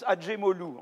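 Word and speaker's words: Acemoglu: D'accord Donc, euh, euh, on prononce Adjemolou, en fait. Hein. voilà. C'est un nom arménien turquisé.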